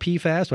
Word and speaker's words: PFAS: So, first of all, the acronym is pronounced PFAS. Do I have that much right? – That's correct.